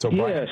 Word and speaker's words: O'Brien: O'Brien, Oregon. Am I saying that right? – It's O'Brien. Yes.